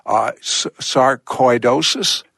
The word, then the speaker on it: sarcoidosis: Sarcoidosis? – Yes, that's correct.